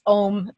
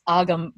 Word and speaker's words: Ogham: One of our assignments, they taught us what's pronounced Om. Some people have corrected me and said it's Agam, but every archaeologist I've ever talked to pronounces it Om, O-G-H-A-M, or H, if you're, you know, from Ireland or Australia, which is an early alphabet that's read vertically, a series of slashes and dots along a line.